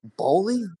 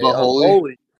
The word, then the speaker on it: Boehly: Boehly. – That when I look at it, that's not how I would pronounce it. But okay, Boehly.